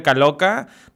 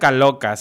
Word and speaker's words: Caloca: Me pueden encontrar como Roger Caloca. Roger se escribe con G, Roger Caloca, C-A-L-O-C-A, igual que como están viendo el nombre de este, de este podcast.